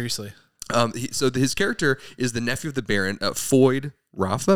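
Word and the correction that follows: Feyd-Rautha: Am I saying that right? – Fade Rotha. Yeah. – Fade Rotha. I wasn't saying it right.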